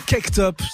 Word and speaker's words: Caked Up: En attendant, je vous propose un remix de ce morceau-là, remixé euh, donc par un gars qui s'appelle Caked Up. Ça s'écrit C-A-K-E-D Up, plus loin.